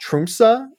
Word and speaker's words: Tromsø: funny enough sold by a small Norwegian airline based out of, uh, I believe I'm, it's pronounced Tromsø.